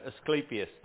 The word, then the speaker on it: Asclepius: Anybody else pronounce it? – Asclepius. – There you go, Asclepius.